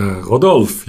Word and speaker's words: Rodolphe: Und heute gehen wir ein bisschen in die Tiefe und zwar spezifisch mit dem Rodolf Lind. Habe ich es richtig ausgesprochen? Er hat ja den Namen gewechselt. Äh, Rodolf, ja, genau.